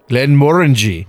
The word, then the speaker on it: Glenmorangie: Glenmorangie. – There you go. – Rhymes with orangey.